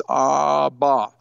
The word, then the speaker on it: Abba: Abba is the easiest word to pronounce. It's a two-syllable word. You open your mouth, ah, and you close your lips once. It's Abba.